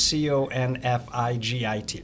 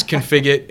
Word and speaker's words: Configit: Configit is Configit. – You got it. You got it. Mispronounced 100 different ways, but it is Configit.